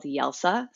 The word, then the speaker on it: Jelsa: I'm living in a town called Jelsa, spelled with a J, but pronounced with a Y, as in Croatian, on the island of Hvar.